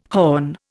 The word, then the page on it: horn: Indian